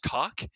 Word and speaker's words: Caulk: And, of course, the chief, whose name is, like, I forget his first name, but his last name is Kalk. But it's pronounced Kalk, but it's spelt like C-A-U-L-K.